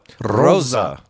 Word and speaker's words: Rosa: But in the Pacific, we have Tropical Storm Rosa. – You're supposed to roll your R on that. – Oh, Rosa.